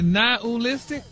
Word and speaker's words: nihilistic: But you actually pronounced it correctly the last time you said it. – Really? Nihilistic? – Wow. – Stop putting the ooh in there. – Nihilistic? – There you go.